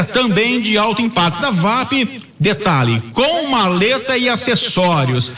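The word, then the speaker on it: WAP: Um prêmio, uma furadeira de impacto da UAP, da VAP é a pronúncia correta, para quem acertar o campeão.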